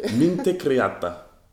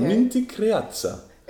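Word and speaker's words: minte creață: Minte creata. – Well, now we are encountering here a very frequent problem that most foreigners have in pronouncing the T with a comma underneath.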